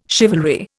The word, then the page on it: chivalry: Indian